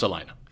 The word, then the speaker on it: Salina: And it's pronounced Salina? – In Salina. – In Salina. – In Salina, it's pronounced Salina.